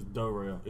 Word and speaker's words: Darrell: No, his name is Darrell.